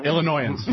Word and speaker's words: Illinoisans: Illinoisans.